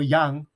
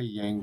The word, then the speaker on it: Yang: And then my last name is Yang, Y-A-N-G, or Yang, as Koreans pronounce it. We don't say Yang, we say Yang.